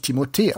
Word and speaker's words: Timothée: Es gibt da herrliche YouTube-Videos und deswegen spricht man ihn eigentlich Timothée aus und nicht mit TH.